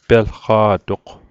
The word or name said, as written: pelqa'toq